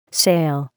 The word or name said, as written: sail